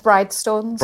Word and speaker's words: Bridestones: But I want to talk about the process that comes from when you find, you were talking about Bristones Moor, where you were based, and you'd walk up to the Bristones. – So it's pronounced Bridestones. – Bridestones, sorry.